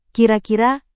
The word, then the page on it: kira-kira: indonesian